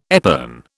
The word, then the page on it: epergne: British English